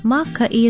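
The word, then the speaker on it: Maka'i (mākaʻi): Maka'i is a pretty handy word when you need help.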